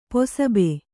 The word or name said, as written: ಪಸುಬೆ